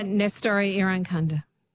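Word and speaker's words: Nestory Irankunda: Nestori Iranchunda? – Yep, Iranchunda? – Nestori Iranchunda.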